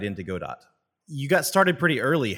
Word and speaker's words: Godot: G O D O T.